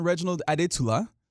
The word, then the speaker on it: Reginald Adetula: My name is Reginald Adetula, or if you want like the full proper pronunciation, Reginald Adetula.